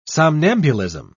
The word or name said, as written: somnambulism